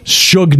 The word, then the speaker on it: Suge: How do you, I don't know how you're supposed to pronounce that. Shug Knight.